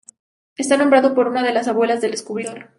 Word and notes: descubridor: spanisch

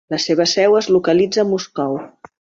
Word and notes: localitza: catalan

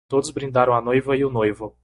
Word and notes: noivo: portekizce

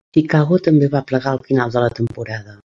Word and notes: Chicago: catala

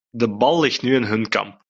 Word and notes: kamp: Dutch

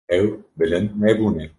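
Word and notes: bilind: Kurdish

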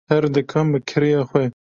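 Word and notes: bi: Kurdish

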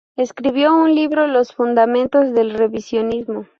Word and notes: fundamentos: ispanyolca

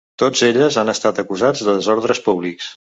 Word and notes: acusats: Catalan

